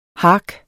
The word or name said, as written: hark